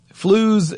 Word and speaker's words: Flooz: Flooz is spelled F-L-O-O-Z, and Beans, B-E-E-B-E-E-N-Z.